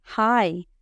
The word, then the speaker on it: high: Hi, hi, es, y se dice igual que hi de hola, se dice exactamente igual, hi.